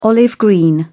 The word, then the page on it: olive-green: British English